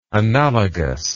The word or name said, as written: analogousness